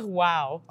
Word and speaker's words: Ughwow: So earlier this year you released your EP Ugnell. Did I mispronounce that? – you mispronounced the easiest word. – Oh, I did? – It's wow.